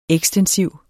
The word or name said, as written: EKSTENSIVT